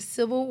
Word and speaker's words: Sybil: My name's Sybil Wardlow. – Okay, let's spell that. Not everybody spells it the same. – S-Y-B as in boy, I-L.